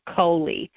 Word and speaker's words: Cooley: And the last name, you pronounce it Coley. And Coley, everybody seemed to get that wrong, but I wanted to share that, yeah, it's pronounced Coley.